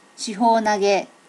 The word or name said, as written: shiho nage